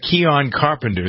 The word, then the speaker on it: Keon Carpenter: All right, so who's Keon Carpenter? Is that how you pronounce his name? – Yes.